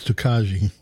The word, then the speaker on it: Tokaji: That's because you can pronounce it wrong. It's Tokaji.